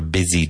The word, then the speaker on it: busy: What does your desk look like after a busy day? Busy, even though it's spelled B-U-S-Y. Busy.